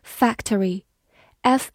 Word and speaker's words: factory: Factory, F A C T O R Y factory，F A C 发 fac，T O 发 t，R Y re factory factory，或者字母 O 的发音可以省略，这样 T 和 R 就构成了 ch 这个音，所以它可以读成 factory 也是可以的。